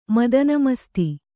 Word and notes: ಮದನಮಸ್ತಿ: madana masti

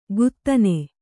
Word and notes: ಕುದನೆ: Kannada

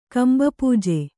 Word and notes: ಕಂಭಪೂಜೆ: Kannada

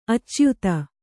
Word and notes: ಅಚ್ಯುತ: acyuta

